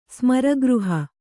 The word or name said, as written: ಸ್ಮರಗೃಹ